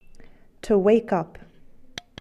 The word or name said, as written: wake up